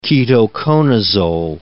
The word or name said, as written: ketoconazole